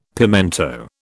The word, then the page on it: pimento: British English